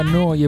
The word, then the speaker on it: Neu: La radio che state ascoltando, dovreste saperlo, è Noi Radio, si scrive Neu, si pronuncia Noi e vuol dire nuova, nuova emittente urbana in diretta da Bologna.